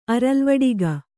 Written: ಅರಲ್ವಡಿಗ

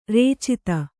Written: ರಚಿತ

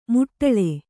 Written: ಮೋಟಾಳು

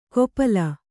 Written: ಗೋಪಾಲ

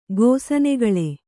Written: ಗೋಸನೆಗಳೆ

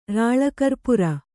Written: ರಾಳಕರ್ಪುರ

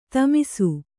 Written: ಥಮಾಸ

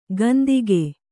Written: ಕಂತಿಕೆ

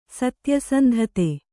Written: ಸತ್ಯಸಂಧತೆ

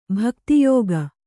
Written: ಭಕ್ತಿಯೋಗಿ

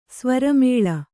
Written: ಸ್ವರಮೇಳ

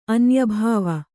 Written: ಅನ್ಯಭವ

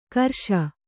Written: ಗಿರೀಶ

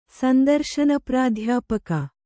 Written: ಸಂದರ್ಶನಪ್ರಾಧ್ಯಾಪಕ

